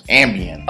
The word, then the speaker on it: Ambien: But is she going to sue them because if she took the ABN and then it made her come, like, you know? – Well, hold up, though. Motherfucking, Damn, hold up. We actually pronounced it as Ambien. – Oh, it's Ambien.